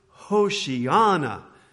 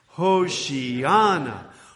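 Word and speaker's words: Hoshiana (הושענא): And let's start off by just saying the word Hoshiana and put the accent on the ana. Can we say that together? Hoshiana, Hoshiana.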